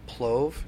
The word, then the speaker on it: plov: So I've been looking at the label for the plove. Is that how you pronounce it? – P-L-O-V. Yes.